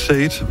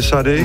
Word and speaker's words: Sade: Og nu er jeg jo allerede i gang med at udtale tingene forkert, fordi jeg vil have sagt Sade, men det er Sade.